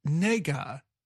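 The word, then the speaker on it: nega (那个): The word is pronounced nega, which sounds like an English word that is offensive to black people.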